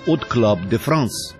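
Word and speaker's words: Hot Club de France: В 1932 году Юг основал от Club de France. Первое H французы не произносят, отсюда и фонетическое от Club de France.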